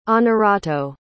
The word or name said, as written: honorato